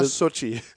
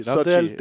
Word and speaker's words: Xochitl: Det er, S-O-H-C-H-E-E. – -E. Sådan skal det udtales. Så det må være Sochi. – Ja. Sochi Gomez. Der er et L midt i det hele også, der jo. Jamen, det er der, men det må være stumt. Det er L. Ja, det staves X-O-C-H-I-T-L.